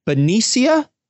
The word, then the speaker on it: Benicia: He's based in Benicia, California.